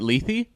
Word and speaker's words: Lethe: Was it Lethe? – Yes. – Ah. – Which doesn't look like Lethe, but I don't know.